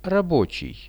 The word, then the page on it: rabochij: Russian